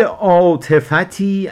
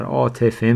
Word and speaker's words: عاطفت: دارم امید عاطفتی از جناب دوست. عاطفت مثل خیلی کلمات عربی دیگه که هم با ت خونده میشه هم بدون تلفظ ت، یعنی ما بیشتر عاطفه میگیم، ولی عاطفت هم داریم، همون جوری که عجلت داریم عجله هم داریم، محلت داریم محله هم داریم، زیاده داریم زیادت داریم.